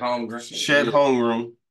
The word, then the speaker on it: Holmgren: Chet Holmgren versus Big France, Victor Wembanyama.